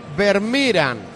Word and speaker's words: Vermeeren: ¿Es Vermeeren o Vermeeren? – No lo sé. – Bueno, escucha, pronunciación oficial, Vermeeren. O sea, yo creo que Vermeeren nos vale.